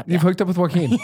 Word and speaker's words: Joaquin: Yeah, Joaquin. – Am I saying that right? – Yeah, Joaquin.